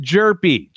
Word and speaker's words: Hjerpe: Uh, they selected Cooper Hjerpe. Is how it's pronounced, everybody. It's spelled H J E R P E we know what you're thinking. It's pronounced Jerpy, 21 year old left hander from Oregon State at number 22 overall.